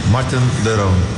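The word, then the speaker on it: Marten de Roon: Martin de Roon.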